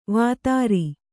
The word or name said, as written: ವೇತ್ರ